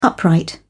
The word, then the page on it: upright: British English